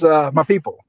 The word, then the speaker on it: Mapipo: Yeah, I'm sure you spelled it correctly, but pronunciation is Mapipo. – My people. – Yeah. – It's yeah, like people.